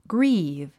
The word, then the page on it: grieve: ɡríːv; グリーブ